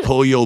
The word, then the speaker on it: Pollo: P-O-L-L-O. – Yeah. – P-O-L. Say that again, sorry. – P-O-A-Y-O. – P-O-L-L-O. Okay. – See, L is the single L and A-Y is the double L.